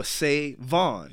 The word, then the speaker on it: SaVon: So is there a difference in pronunciation with that big V? – No, say Vaughn. Yeah. That's why the V is emphasized. – It's like the separator. Mm-hmm. – Yeah. – It's like a say Vaughn.